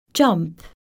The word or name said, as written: jump